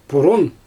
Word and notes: purun: Xyzyl

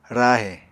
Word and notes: rahe: Apatani

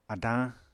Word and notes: adañ: Apatani

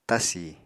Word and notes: tasi: apatani

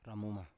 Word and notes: ramuma: Biak

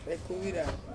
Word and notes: kuira: Ansus

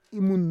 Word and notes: imun: Roon

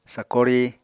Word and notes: sakori: apatani

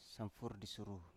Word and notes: samfur di suru: Biak

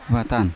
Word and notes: vatan: Yawa

